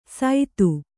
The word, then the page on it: ಸೈತು: Kannada